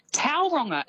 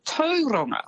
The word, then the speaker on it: Tauranga: and Tauranga as well as actually Tauranga.